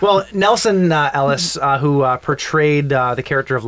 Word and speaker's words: Nelson Ellis: Nelson Elias, I believe that's how you say his name. – Ellis, dude. – Nelson Ellis. It could be Elias. – It's E-L-L-I-S. – L-I-S. Oh L-I-S.